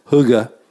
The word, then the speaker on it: hygge: It was more about the feeling that what we called in previous episodes hygge, which is hygge.